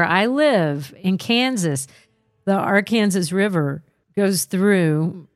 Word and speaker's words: Arkansas: So every time I see it, I know in connection to Tulsa, it's pronounced Arkansas River.